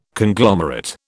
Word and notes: Conglomerate: British English

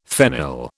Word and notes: phenyl: British English

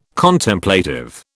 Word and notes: Contemplative: British English

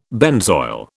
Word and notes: benzoyl: British English

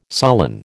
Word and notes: sullen: American English

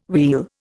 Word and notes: wheal: Indian

